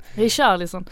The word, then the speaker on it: Richarlison: Richarlison? – Ja, precis. – Han är också bara 20. – Richarlison.